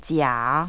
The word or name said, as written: jia